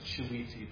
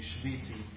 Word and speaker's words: shiviti: You would say in modern Hebrew, shiviti. I pronounce it shiviti, but modern Hebrew would be shiviti.